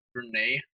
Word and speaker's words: Brené: I think it's Brené, I believe. Okay. That's how you pronounce it.